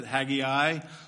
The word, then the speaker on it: Haggai: I've had probably 3 or 4 people ask me already this morning how it's pronounced, and I'm going to pronounce it Haggai. No shame if you want to pronounce it the other way.